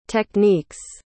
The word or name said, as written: techniques